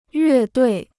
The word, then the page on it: 乐队: yuè duì